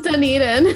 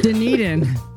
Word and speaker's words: Dunedin: I should have asked you how to pronounce that. How do you say that? – It's Dunedin. – Dunedin. There you go.